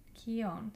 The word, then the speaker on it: Keown: Keown. Say it right. Jerome Keown. He has a very, very hard name to say.